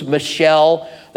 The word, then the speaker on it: Michal: Michael is kind of the Americanized way of doing it. I've heard people use Michelle. That's kind of making it American as well. It's spelled M-I-C-H-A-L, so Michelle does work. As near as I can tell, I'm not a Hebrew expert, okay? But as near as I can tell, in Hebrew, it's Michal. It's got that little bit that going on in it, like a lot of Hebrew words do.